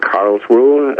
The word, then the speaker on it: Karlsruhe: And another event worth mentioning is the 11th of June, where we will be in Karlsruhe, or you would pronounce it Karlsruhe.